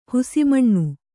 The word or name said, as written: ಹಸುಮೀನು